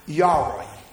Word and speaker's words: yare: The Hebrew of the word fear is the word yare. It's spelled Y-A-R-E. Yare.